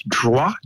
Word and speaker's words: droit: And in French, droit, D-R-O-I-T, droit, In French, if the word finishes with a consonant, like T, you don't pronounce it unless there's a vowel after it. So P-A-R-I-S, there's no vowel after the S, so you don't pronounce the S.